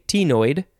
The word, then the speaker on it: ctenoid: Next is, you can pronounce it a couple of ways. I want to say tenoid. You could also just say, instead of T, just tenoid, I think. Give or take. I like tenoid. It is spelled C-T-E-N-O-I-D.